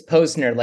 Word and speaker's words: Posner: It's Posner, like Edgar Allan Posner.